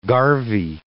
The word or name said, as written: garvey